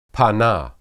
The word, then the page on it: panah: hebrew